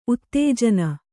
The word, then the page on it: ಉತ್ತೇಜನ: uttējana